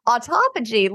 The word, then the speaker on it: autophagy: And if you constantly have stuff coming in, which comes to the stuff around, is it called autophagy? Is that how you say it? – Autophagy. You did it. You nailed it.